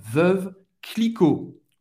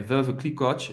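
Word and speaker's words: Veuve Clicquot: E « Et ou en français, tem o som do E, hein? veuve, você vai acabar no segundo V, veuve, non va prononcer o E, Clicquot. Clicquot. « O T non va ser pronuncia, euh, hein, Non, Hein, não veuve Clicot » et « veuve Clicot.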